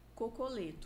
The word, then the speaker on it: Cocoletto: Tose Cocoleto.